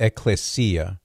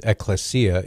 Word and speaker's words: ecclesia: It's actually pronounced ecclesia. So for what it's worth, ecclesia, it just means an assembly, a gathering.